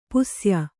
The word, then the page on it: ಪುಸ್ಯ: Kannada